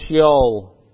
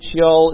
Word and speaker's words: Sheol: The Old Testament Hebrew word for hell is Sheol. I don't know if I'm pronouncing that right. S-H-E-O-L.